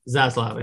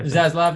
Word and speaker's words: Zaslav: Zaslav. – Zaslav, yeah.